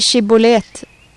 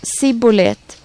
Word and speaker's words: Shibbolet: Svarade han då nej, sa de till honom: säg Shibbolet. Sa han då Sibbolet, för att han inte kunde uttala ordet rätt, grep de honom och dödade honom där vid Jordans vadställen.